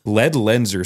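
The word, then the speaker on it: Ledlenser: LED Lenser. So it's LED LENSER.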